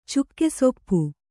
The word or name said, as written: ಚುಕ್ಕೆಸೊಪ್ಪು